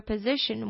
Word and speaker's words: position: What's your position? What's your location? 这种感觉。那这个 O 呢，它的发音则是的音，所以是 position。